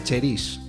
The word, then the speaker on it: Cherise: Queremos saber cómo se pronuncia bien tu nombre. Yo lo he escuchado, cómo se escribe, Cherise, pero también se dice Cheris, ¿no? – Sí, se pronuncia Cheris, pero vamos, que cada uno puede llamarlo como quiera.